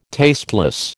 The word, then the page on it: tasteless: American English